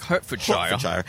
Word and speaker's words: Hertfordshire: It's from Hertfordshire. – Hertfordshire. Yeah. But you have to say it like Hertfordshire.